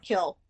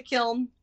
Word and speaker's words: kiln: My mom calls it kill, but I say kiln. All of the people I know in the art world. – Yeah. There's an N. – Yeah. There's an N. We got to pronounce it.